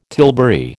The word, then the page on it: tilbury: American English